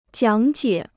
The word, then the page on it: 讲解: jiǎng jiě